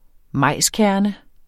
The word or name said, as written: majskerne